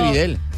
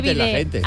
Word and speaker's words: bidé: Que no se dice Videl. – Que no se dice Videl, se dice Bidé.